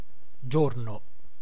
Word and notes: journée: [ʒuʁ.ne]; France

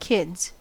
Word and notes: lapset: /ˈlɑpset/

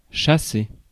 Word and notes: chasser: [ʃa.se]

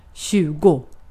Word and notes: tjugo: /ˈɕʉːˌɡʊ/; /ˈɕʉːˌɡɛ/

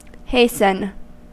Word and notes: hasten: /ˈheɪs.ən/

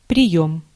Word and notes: method: American English; /ˈmɛθəd/

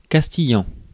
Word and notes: castillan: France; [kas.ti.jɑ̃]

